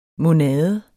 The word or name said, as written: monade